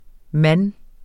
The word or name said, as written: mand